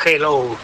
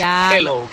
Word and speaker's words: hello: Y la segunda, dile a Elena que su dicción en castellano es la más perfecta que conozco, porque cuando diga hello, que no pronuncie la J, que no es hello, que es hello, que la aspire la J y no pronuncie la J potente castellana.